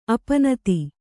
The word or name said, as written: ಅಪ್ಪುನಿಧಿ